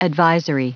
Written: advisory

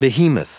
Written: behemoth

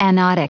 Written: anodic

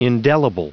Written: indelible

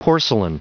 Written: porcelain

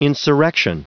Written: insurrection

